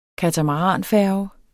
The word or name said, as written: katamaranfærge